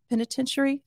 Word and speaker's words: penitentiary: So Crossroads Penitentiary. Penitentiary. I was adding an extra syllable in there.